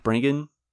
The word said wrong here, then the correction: Branagh: Oh, is it Kenneth Branagh? Is that how you pronounce it? – Branagh. Oh, yeah. Sorry, I'm illiterate. – Sure. I don't even know. – I forget about those silent G's and H's and words.